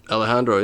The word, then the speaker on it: Alejandro: Alejandro? – Alejandro, yeah, you got it.